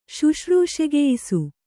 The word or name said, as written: ಶುಶ್ರೂಷೆಗೆಯಿಸು